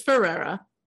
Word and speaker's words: Ferreira: Is that not how you say it? Did I already fuck it up? – I think it's just Ferreira, right? – Like American Ferreira. – I think it's just Ferreira. – Here I am, the expert on the drama, and I don't even know how to say her last name. I've always said Ferreira. – I mean, our fans, please correct me if I'm wrong. – No, you're not. It's Ferreira.